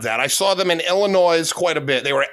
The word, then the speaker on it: Illinois: And by the way, just so you know, it's Illinois. – It's okay. Fine. The S is silent. There was a ton of them in Illinois.